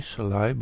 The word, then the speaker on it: Selye: The word stress was already used in the work of Hans Selye, S-E-L-Y-E, Selye, but you pronounce it Selye.